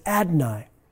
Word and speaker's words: Adonai: It was such a holy name that they barely said it and used a lesser Hebrew word pronounced Adonai, meaning my God.